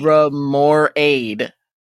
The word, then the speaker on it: Remoraid: See, R-A-I-D at the end? What is that? That's Raid. – Remoraid. See? Rem or aid. Remoraid.